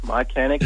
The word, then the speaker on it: Mychanic: Mychanic is the correct pronunciation.